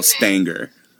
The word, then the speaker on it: Stanger: Stanger? – It rhymes with hanger. – So okay. Stanger. Elle Stanger.